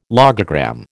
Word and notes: logogram: American English

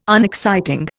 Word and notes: unexciting: Indian

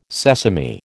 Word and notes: sesame: American English

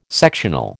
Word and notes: sectional: American English

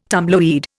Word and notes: tumbleweed: Indian

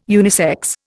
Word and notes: unisex: Indian

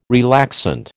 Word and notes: relaxant: American English

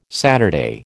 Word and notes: Saturday: American English

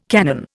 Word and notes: cannon: Indian

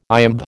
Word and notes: iamb: American English

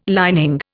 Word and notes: lining: Indian

